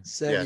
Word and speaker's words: Serie A: Yeah, Serie A.